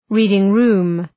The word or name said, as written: reading-room